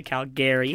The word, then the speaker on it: Calgary: There's an A, so you pronounce it Calgary.